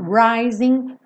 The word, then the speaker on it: rising: Rising frequentemente, 99,99% das vezes, um G numa palavra com ING não é pronunciada. São pouquíssimos sotaques no mundo que pronunciam esse G.